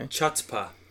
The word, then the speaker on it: chutzpah: Chutzpah.